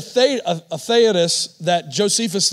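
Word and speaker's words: Theudas: Caden told me how to pronounce this first guy's name, Theodos.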